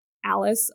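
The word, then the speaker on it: Alys: One of his wives, Queen Alice, I think it's Alice. George also loves doing that, where I'm like, it's A-L-Y-S, but it's probably just pronounced Alice.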